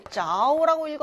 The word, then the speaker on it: 着: 睡着了라고 읽고 睡가 동사 그리고 여기서는 자오라고 읽어줘야 맞겠죠.